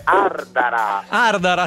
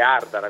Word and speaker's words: Ardara: Comunque Federico, è Ardara, accidenti, ho sbagliato, scusami. – Sai che noi sardi ci teniamo tanto, quindi è Ardara, con l'accento sulla prima sillaba.